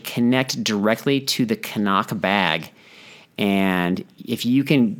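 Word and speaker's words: Cnoc: But anyway, so there's an aftermarket bag called, I think you pronounce it Knock.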